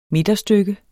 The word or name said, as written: midterstykke